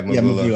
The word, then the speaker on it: Mvula: Do you mm. pronounce it Mvula? Mvula, Mvula, must Mvula, be. yeah, Mvula. yeah, We're American, yeah.